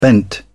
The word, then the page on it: bent: British English